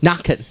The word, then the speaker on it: Naquin: Naquin. Because that's the correct way to say his name. It's a French pronunciation.